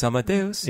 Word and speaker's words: Amadeus: Amadeus.